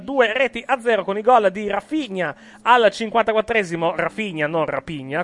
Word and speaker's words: Raphinha: No, io ce l'ho con l'H qui, R-A-P-H-I-N-H-A. La pronuncia dovrebbe essere Rapinha. – No, Rafinha dovrebbe essere la pronuncia, se quella...